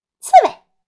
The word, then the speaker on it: 刺猬: 整体认读音节，刺猬，尾可是轻声，刺猬。